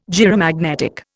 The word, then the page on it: gyromagnetic: Indian